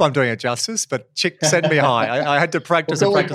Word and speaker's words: Csikszentmihalyi: So flow is a concept, and it's often talked about these days in the circles of positive psychology, but a psychologist called Chimineski, you know, I would have mispronounced it and I apologize. – It took me 3 years doing my master's. Csikszentmihalyi.